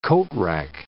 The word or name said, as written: coatrack